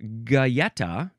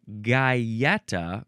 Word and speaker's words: Galleta: Next we have Gaeta, or Gaeta, which is a very slightly, only a tiny little difference in the pronunciation there. So anyway, Galleta, G-A-L-L-E-T-A, noun from 1872.